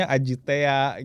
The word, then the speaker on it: AJT: Iya, AJT gitu kan kalau di Bandung, saya asal Bandung, AJT itu dibacanya Ajitea gitu.